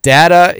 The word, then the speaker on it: data: Data is the correct pronunciation. Dat is not pronounced date, or it would have an E on the end.